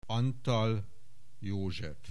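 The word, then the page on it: Antall József: Hungarian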